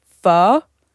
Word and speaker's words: pho: I used to get delivery pho, which is how you pronounce it.